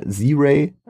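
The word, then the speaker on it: Zry: Z-Ray, also Z-R-Y, Z-Ray. Und ähm, der, der Track ist gespickt mit roughen Bars.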